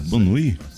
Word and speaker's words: bonne nuit: Bon nuit? Bon nuit. – Bon nuit. – Rapaz, não sabia que era francês, não. Bon nuit, então. – É assim, ó. Bonne nuit. Mas a pronúncia é bon nuit.